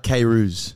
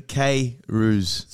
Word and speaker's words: Kayrouz: How do you pronounce your last name? – It's John Bernard Kayrouz. – Kayrouz. – Yeah, so phonetically Kayrouz.